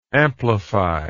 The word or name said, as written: amplifiable